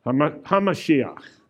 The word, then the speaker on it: Hamashiach: Hamashiach. Hamashiach. The is Ha. Mashiach. They form one word together.